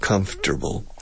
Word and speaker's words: comfortable: Both comfortable has five syllables. Four syllables. Comfortable. Comfortable. También en español tiene cuatro sílabas. Vegetable. Vegetable. Four syllables. But when we speak, we never use four syllables. We reduce it to three.